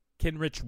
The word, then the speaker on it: Kenrich: Do you want Kendrick Kendrick uh, Kenrich Williams? Finally, got the name right.